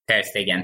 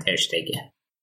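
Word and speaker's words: Ter Stegen: دقیقا ترستگن تلفظ درست درستش درستش درست درستش ترستگنه ولی خب توی بیشتر آلمانی ها میگن ترشتگه بسیار بریم سراغ بوندس بریم آقا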